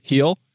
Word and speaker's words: Gil: Yeah, Gil, soft G, Gil.